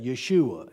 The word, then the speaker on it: Yeshua: And his name actually is Yeshua. That's how you pronounce that, Yeshua in Aramaic.